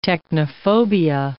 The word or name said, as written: technophobia